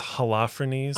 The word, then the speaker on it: Holofernes: In my mind, I was reading it Holofernes, but it's Holofernes. – I'm saying Holofernes. – Holofernes, okay. Because you've got, that part just makes the most sense. You've got Holofernes winning, conquering a lot of whatever countries.